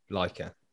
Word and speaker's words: Laika: Laika. It's Laika, man. Pronounce it properly, it's Russian.